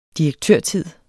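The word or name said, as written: direktørtid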